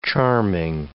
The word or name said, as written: charming